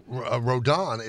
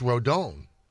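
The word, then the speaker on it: Rodón: And then they also pronounce Rodon. It's Rodon.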